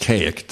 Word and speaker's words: Kazik: K-A-Z-I-K, pronounced Kaik. Silent Z, I think.